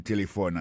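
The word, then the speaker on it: telephone: Téléphone, c'est comme ça on dit téléphone en anglais. Nous devons répéter encore pour nous, téléphone. N'a pas remarqué que l'accent tonique l'a tombé sous premier syllabe là.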